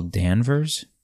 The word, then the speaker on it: Danvers: So, Brie Larson played Carol Denvers. No, I'm sorry, Carol Danvers. Carol Danvers, that's how you pronounce it, or Captain Marvel.